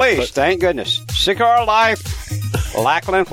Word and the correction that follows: Lakeland: Florida. – Lakeland. That's seven.